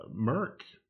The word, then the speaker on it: Merck: Merck, is that how you pronounce that? – Yep.